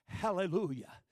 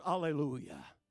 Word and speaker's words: hallelujah: But the word itself, hallelujah, sometimes pronounced alleluia.